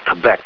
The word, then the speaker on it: Quebec: The French-Canadian province of Quebec is pronounced as if it started with a K, but that's actually the phonetic word for the letter Q.